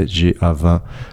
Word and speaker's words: GA-20: GA20, prononcé en français GA20.